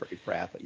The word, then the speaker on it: Ephrathah: I think Bethlehem Ephrathah, or however you pronounce it. – Ephrathah, yeah.